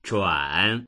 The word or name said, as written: zhuan3